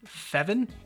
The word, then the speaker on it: Feven: How would you say this? F-E-V-E-N. Feven? – It's like Kevin, but with an F.